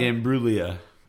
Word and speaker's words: Imbruglia: Imbruglia.